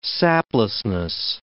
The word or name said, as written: saplessness